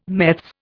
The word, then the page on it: meths: Indian